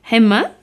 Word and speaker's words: Emma: On peut trouver ce morceau sur le CD Emma, c'est bien ça, que c'est comme ça qu'on le prononce, Martin ? – Exactement.